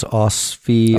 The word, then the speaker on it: OSFI: Now, how do I say this again? It's OSFI, OSFI, OSFI whatever. OSFI, Offside. There go.